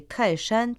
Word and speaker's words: 泰山: Тхай в названии Тхайшан происходит от названия города Тхайан, произносится в четвертом тоне, а Шан – это горы, гора, произносится в первом тоне.